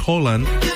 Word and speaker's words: Haaland: Que como dato curioso no se dice Haaland, se dice Holland. Porque la doble A en noruego se dice, pronuncia como una O.